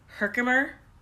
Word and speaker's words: Herkimer: You're from, I'm probably going to say it wrong, Herkimer? New York? – That's good. Yeah. Oh, you got it.